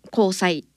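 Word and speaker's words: 虹彩: 交際っていうのは虹と書いて、それからあやどる、色彩の際ですね。で、交際と読みます。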